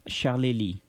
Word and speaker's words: Charlélie: comment tu prononces son nom? – Charlélie. – Charlélie, oui.